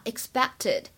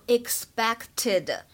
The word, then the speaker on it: expected: 在这段话的朗读过程当中呢，首先我们需要注意一下 expected，它呢当中有一个完全失去爆破的现象，希望各位同学不要把这个单词读成 expected，因为呢当中其实当这个 k 和 t 这两个爆破音相遇的时候呢，前者就已经被撞飞了啊，只做了口型，没有发出声音来。